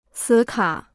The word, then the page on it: 磁卡: cí kǎ